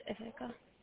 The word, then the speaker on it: Ithaca: How do you pronounce it? Utica, and the other one is Ithaca? – Yeah.